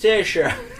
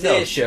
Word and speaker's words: Saoirse: Oh, and Saoirse Ronan's first name is pronounced Saoirse. – Yeah, because we didn't know. We didn't Saoirse.